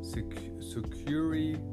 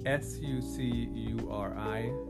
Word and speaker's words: Sucuri: Um, so, I emailed uh, my web developer Matt Thiessen, and he suggested I should reach out to Sucuri, Sucuri. I think that's how you pronounce it. S U C U R I, Sucuri Sucuri. And what's interesting is I actually knew that name.